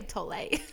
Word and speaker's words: Tolle: Or how do you say it? – I say Tolle. – Oh, Tolle.